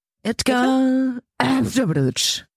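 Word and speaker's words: Edgar Rinkēvičs: Edgar Rinkiewicz oder so. Sorry, wenn ich es falsch ausspreche, aber... Ja. – Sehr, ich finde gut, dass Räuspern davor. Edgar Rinkiewicz. – Ja, richtig, genau, ja.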